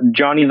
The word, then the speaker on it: Jonny: And then if you want to see me personally, it's Johnny the Mill on Instagram. That's J-O-N-N-Y, no silent H in my name. – Okay, there you go. J-O-N-N-Y, The Mill, T-H-E-M-I-L-L? – Correct.